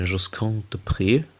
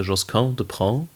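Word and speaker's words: Josquin des Prez: Wir nehmen gerade eine Josquin de Pré CD auf. Oder wie neulich ein Kumpel sagte, Josquin de Pré. So wird er nicht ausgesprochen.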